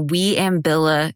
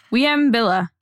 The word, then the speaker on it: Wieambilla: So today we're going to be talking about the Wieambilla shooting. Yes, that is how you say it. Say it. – Wieambilla.